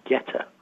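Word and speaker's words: goetta: But white pudding is quite similar to the Cincinnati food of Geta. I think I'm pronouncing it correctly. – Oh, yeah. I can confirm that.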